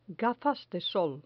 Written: gafas de sol

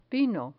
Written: vino

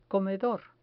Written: comedor

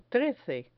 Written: trece

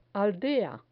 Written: aldea

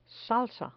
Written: salsa

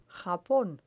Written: japon

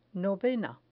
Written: novena

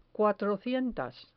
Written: cuatrocientas

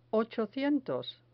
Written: ochocientos

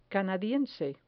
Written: canadiense

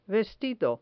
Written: vestido

